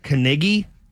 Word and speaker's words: Knigge: His name is Ron Kniggy, K-N-I-G-G-E. Got to really enunciate that perfectly so I could come to work tomorrow.